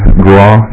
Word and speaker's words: Grawe: My name is Doug Graw, G-R-A-W-E is how you spell that.